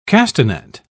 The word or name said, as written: castanet